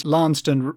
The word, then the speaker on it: Launceston: Is that L-A-U? – It's L-A-U-N-C-S-T-O-N. – Oh, yes. – I think it's pronounced Launceston locally, but I'm from the north and refuse to pronounce it like that.